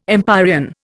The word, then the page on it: empyrean: Indian